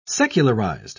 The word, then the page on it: secularized: British English